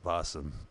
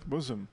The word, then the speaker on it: bosom: Blossom. Blossom? – Bosom.